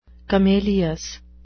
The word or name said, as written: camelious